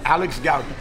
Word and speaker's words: Alex Goudie: How do you say your name, first of all? – Alex Gaudi. – Gaudi, there you go.